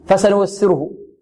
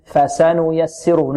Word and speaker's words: فسنيسره: فسنيسره، ربما البعض يقول فسنيسره، لا، فسنيسره، نعطي الفاء الفتحة التي على الفاء نعطيها قدرها،